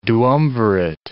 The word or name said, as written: duumvirate